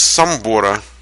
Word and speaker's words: Самбор: Из Самбора. А я правильно сказал, Самбора, так. Львовской области. Из Самбора. Я сначала сказал правильно, сказал Самбора, а потом прочитал, что ударение на О.